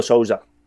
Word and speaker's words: Sousa: Sousa, yeah.